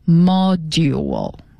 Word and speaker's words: module: 它是 M 开头的，它叫 module， M-O-D-U-L-E， module。那么这个 D 和 U 放在一起发音的时候就发成 drill 发成 zh 的音 module。